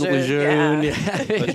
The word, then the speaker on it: Lejeune: And as a Marine Corps based podcast, we are no strangers to the arguments and food fights over pronunciation, aka Lejeune. – Lejeune.